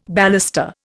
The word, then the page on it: baluster: Indian